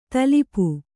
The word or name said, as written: ತಲುಪು